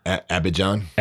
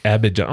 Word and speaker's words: Abidjan: Say that for me. – Abidjan. – Abidjan. Oh, yeah, I was saying it right.